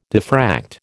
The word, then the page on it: diffract: American English